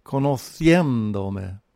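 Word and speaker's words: conociéndome: För att uttala conociendome korrekt på spanska måste vi betona det första et. Det måste vi markera i stavningen genom att sätta en accent över et. Vi får alltså conociendome.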